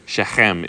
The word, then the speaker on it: Shechem: Or Shechem, is it properly pronounced, which will be the last time you hear me say it like that, okay? Because I am not a Jew, okay? But they have that that we don't have, and you're going to have to work on it when you get to Israel. So Shechem is how it's pronounced.